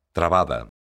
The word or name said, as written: trabada